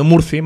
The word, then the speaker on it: Murphy: o Murphy, se pronuncia más con una aune neutra que suena más a U que a A. De hecho, diciendo Murphy no te acabarían de entender, diciendo Murphy, más cerrada la U, sí te entendería.